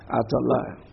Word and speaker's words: Athaliah: How did you pronounce it? – Athaliah. – All right.